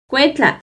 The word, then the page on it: cuetla: Náhuatl